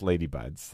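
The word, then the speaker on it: Leis de Buds: Well, first of all, it's pronounced Ladybuds, like L-A-D-Y-B-U-D-S. That's how you pronounce it. – That's right. – But that's not how you spell it. – It's L-E-I-S, like a Hawaiian lei. – Yeah. – D-E of buds, B-U-D-S. Because that's what leis are. They're leis of flower buds.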